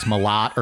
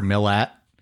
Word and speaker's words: Milat: We didn't know if it was Milat or Millet. – Yeah. – And I think it just depends on your accent. You'd be like, Milat. – Yeah, it's like somewhere between Millet and Milat, like between the E and the A, but there's not, that vowel sound doesn't really exist in our language.